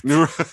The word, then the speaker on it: Eruditorum: Eruditorum. – I wasn't even close. – It's basically erudite, but like, you know, it's a play on the word erudite.